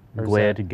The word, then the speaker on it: GUID: You said GUID. I always say GUID. Have I been saying it wrong this whole time? – GWID, GUID. – Okay. – I remember, I go for a shortening as possible. You have two syllables, I go one syllable.